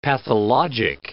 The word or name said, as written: pathologic